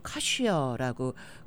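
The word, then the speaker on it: cushaw: 올해의 결정적인 우승 단어는 바로 커셔라는 거랍니다. 이게 CA SHAW, 우리 캐시넛하고는 발음이 조금 비슷하지만 철자가 달라서 아주 헷갈리기 쉬운 건데요. 이거는 호박, 이렇게 북미 지역에서 자라는 구부러진 큰 호박을 카시어라고 그런다고 해요.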